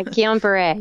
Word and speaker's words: Guillain-Barré: MS or Guillain-Barré. – Sure. – I don't know if I pronounced that right, but yeah, Guillain-Barré.